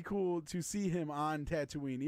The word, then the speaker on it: Tatooine: So I think it would be cool to see him on a, oh my God, I'm about to butcher the name, Tatooine, right? – Yeah, you're good.